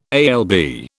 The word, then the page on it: alb: British English